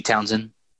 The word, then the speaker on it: Townshend: But he took a Who sample by, is it Townsend? I'm reading it Townshend, but like I've always heard Townsend, right? – Yeah, Pete Townsend. – Yeah, Townsend, yeah. Okay. I put the emphasis on the wrong syllable.